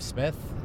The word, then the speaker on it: Smith: My name is Jeff Smith, and you spell it J-E-F-F-S-M-I-T-H.